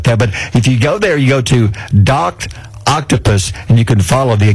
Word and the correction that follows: Doctopus: You said it wrong. – What? – It's Doctopus.